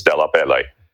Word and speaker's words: DellaPelle: But our Americanization of the name was to chop off the E in pronouncing it. My grandparents did that. But when I go there, and I have, it's DellaPelle.